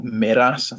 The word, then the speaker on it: Meraas: Mark Johnson horse called Meras, I think it's pronounced, M-E-R-A-A-S.